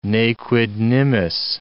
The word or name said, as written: ne quid nimis